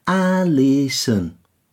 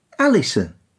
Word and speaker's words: Alison: It's the O that causes the biggest problem, isn't it? – Yeah, people tend to overemphasise it, whereas a native English speaker hardly pronounces it. Alison. – Yeah, it's a schwa.